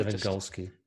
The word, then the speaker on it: Robert Lewandowski: You're not only relying always on Robert Lewandowski, but Thomas Müller's also played his part. So he's a big miss. – Lewandowski, I think you pronounce that. Golski, remember? – Lewandowski, yeah.